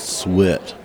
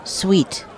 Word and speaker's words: Suite: I think that's suet. – No, no, dude, you're both wrong. It's pronounced suet. You know, like the stuff that they put into birdseed? – Guys, it's pronounced sweet. The Nutcracker Suite.